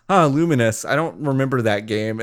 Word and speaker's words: Lumines: I was today years old when I found out that's pronounced Luminous and not Lumines, which I've been saying for fucking ever.